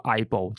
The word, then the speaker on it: AIBO: 这一次他们透过 AI 升级的产品名称叫做 AIBO，然后念作 IBO，其实是取自日文的谐音，汉字写作相棒，那实际上就是伙伴的意思啊。